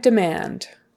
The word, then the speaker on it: demand: Drive demand, drive demand. So we say demand, demand. It's really fast that first syllable because the accent is on the or the stress is on the second syllable.